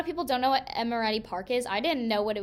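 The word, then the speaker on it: Emeriti Park: The next place that is a favorite at OU is, please tell me if I say it wrong, but Amoretti Park. – That's right.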